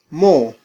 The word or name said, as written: mo